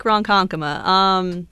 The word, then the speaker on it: Ronkonkoma: Yes, Lake Ronkonkoma. You did pronounce it correctly.